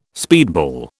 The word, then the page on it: speedball: British English